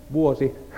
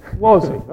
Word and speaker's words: vuosi: Vuosi. – Vuosi.